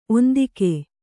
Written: ಒಂದಿಕೆ

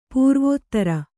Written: ಪೂರ್ವೋತ್ತರ

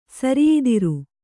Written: ಸಿರಿಯದರ

